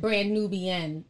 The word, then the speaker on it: Brand Nubian: So now what I'm going to do, is it Brand Nubian? Sorry if I mispronounce it. Brand Nubian. I think I said Brand Nubia. But Brand Nubian was his group.